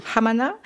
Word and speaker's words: Hamana: Un village qui s'appelle Hamana, c'est comme ça qu'on dit ? – Vous prononcez très bien. – Hamana, qui est réputé pour ses cerisiers et ses pommiers.